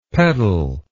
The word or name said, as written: pettle